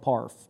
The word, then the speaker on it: PARF: Bit of a mouthful, we call it PARF. I know it rhymes with barf.